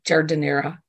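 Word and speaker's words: giardiniera: jus gardenera. I can never pronounce that properly. – Yeah, it's I always say jardinera.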